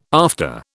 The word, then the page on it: after: British English